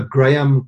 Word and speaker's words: Graeme: So Graham Codrington. Graham is spelled G-R-A-E-M-E.